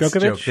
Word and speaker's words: Djokovic: Yes, Djokovic. The D is silent.